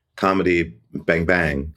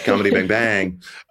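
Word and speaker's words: Comedy Bang! Bang!: Yeah, that was, I think I just stopped doing comedy Bang Bang. – Right. – Oh, wait, so the emphasis is on the second Bang.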